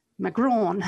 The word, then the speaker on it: Macron: Is it Macron? Okay, Macron.